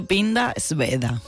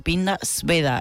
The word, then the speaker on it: Pinda Sveda: Se dice Pinda Sveda. – Sveda. Ah, la W como si fuera una B, ¿no? – Es sí, de hecho, a veces se escribe con una V, Pinda Sveda, S-V-E-D-A.